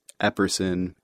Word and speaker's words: Epperson: My name is spelled M-E-G-H-A-N, and Stagel is S-T-A-G-L. – Okay, Matthew? – On Facebook, you can find me at Matthew, M-A-T-T-H-E-W, J, Epperson, E-P-P-E-R-S-O-N.